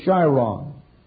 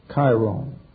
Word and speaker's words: Chiron: The most noted of centaurs in classic fable is Chiron, or as some pronounce it, Chiron.